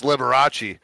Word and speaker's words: Liberace: I'm going to drink now because of how badly I just fucking pronounced that. Liberus, and it's Liberace. All right.